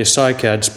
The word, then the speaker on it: cycads: Cycads, Bhante, cycads, pronounced cycads.